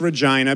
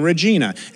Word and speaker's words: Regina: R-E-G-I-N-A, and I know it's pronounced Regina because on stage, I would say it's nice to be in Regina. And they come up to you afterwards and they go, it's Regina, like vagina.